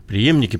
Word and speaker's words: преемники: графе преемники. Преемники, правильно говорите? Ну, не Прие... преемники. Преемники. Наверное.